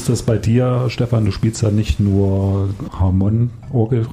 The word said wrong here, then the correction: Hammond: Habe ich das jetzt richtig ausgesprochen? – Gar nicht, das war alles falsch. – Aber du spielst ja nicht nur Hammond-Orgel, sondern auch Gitarre und so.